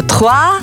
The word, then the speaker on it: trois: А ну, да, я правильно вимовляю? Трис? – Ну, практично, тхуа. – Ага, тхуа.